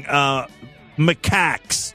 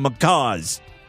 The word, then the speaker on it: macaque: Yes, I fucked up on pronouncing macaques. Macaque monkeys. I call them macaws.